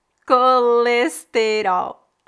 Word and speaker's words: colesterol: Colesterol. Colesterol. Parece que em Portugal você tem menos sílabas, né? Em português do Brasil, Colesterol. Quatro sílabas. Em Portugal, colesterol. Duas sílabas, colesterol.